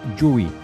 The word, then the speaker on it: Dewey: Jo m'atreviria a suggerir la pronunciació Dewey.